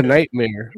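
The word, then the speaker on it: Knightmare: Nightmare with a K. – This K is silent, man.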